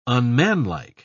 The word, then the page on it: unmanlike: British English